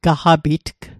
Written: gaqapitg

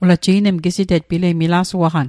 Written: gisite'tg

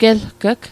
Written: gelg'g

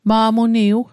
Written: ma'muniw

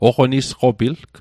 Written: oqonisqopilg

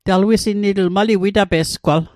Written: witape'sgwal

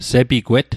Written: sepigwet

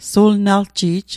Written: sulnalji'j

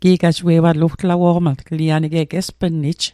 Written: gi'gajie'watl

